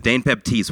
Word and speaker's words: Dane Baptiste: His name is Dane Baptiste.